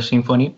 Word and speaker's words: Symfony: Y entonces, claro, eh, lo normal que se utiliza en PHP ahora, sin, lo más estándar es Symfony, eh, Symfony mm. como lo llamamos nosotros, ¿no? Como se pronuncia en inglés,